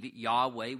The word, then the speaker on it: Yahweh: Verse 1 opens by telling us, notice, the Lord said to my Lord, and notice the distinction there, capital L-O-R-D, that's that Hebrew tetragrammaton, the Y-H-V-H, we believe it's pronounced Jehovah, or some believe Yahweh. We can't be 100% certain.